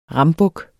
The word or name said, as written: rambuk